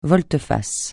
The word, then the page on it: volte-face: francais